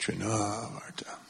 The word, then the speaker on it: Trinavarta: It's pronounced Trinavarta. We never say it like that, though, do we? We always do that wrong. A lot of names have accents in a certain place, but we have our American way of doing it.